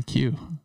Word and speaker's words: queue: So we've got a couple good topics in the queue. So hopefully we'll get to those. – That's one of my favorite words because it's one letter followed by four silent letters.